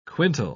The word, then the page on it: quintal: British English